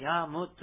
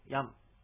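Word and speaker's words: ヤㇺ: カタカナでヤームとなってますが、ムというのはちっちゃい、ムですので、口を閉じるだけの発音なので、ヤームってこう、はっきり言うんでなく、ヤムという発音になります。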